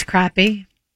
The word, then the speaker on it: crappie: And when the cops got out there and stopped the two men who were fishing, they were 253 crappies over the limit. That's right. 253 crappies over the limit. Am I saying that right? Is it crappie or crappy? – It's crappy.